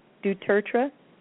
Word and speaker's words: du Tertre: And I tell them if they get really confused, just think of two turtles. Two turtles. Tertre.